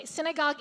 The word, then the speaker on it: sinigang: Ijaleth, I don't think she's here today, but she's Filipino, and she took me to a Filipino restaurant, and we had sinigang. Am I pronouncing this? Okay, sinigang, it's a soup, and it is so good.